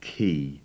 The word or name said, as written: key